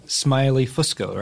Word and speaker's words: Smiley Fusco: And, Eric, you were in the latest season, Twin Peaks Returns, and you played the detective, Smiley Fusco, right? – I did.